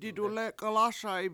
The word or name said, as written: didulEk$1lASa7i